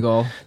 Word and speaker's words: Donegal: Donegal.